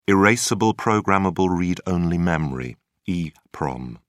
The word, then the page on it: erasable programmable read-only memory: British English